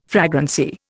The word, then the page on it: fragrancy: Indian